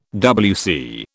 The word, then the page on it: WC: British English